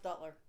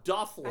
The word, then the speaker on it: Duthler: D-U-T-H-L-E-R. – It's Duffler. – It's pronounced Duttler. – If you say Duttler, it's because you're fucking retarded. It's D-U-T-H-L-E-R. Duffler.